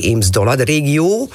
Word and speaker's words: Eems-Dollard-regio: En ook de EDR, die Eems-Dollard-regio. In Duits zeg ik dat goed zo? – Dat zeg je heel prima, ja.